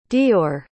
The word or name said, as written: dior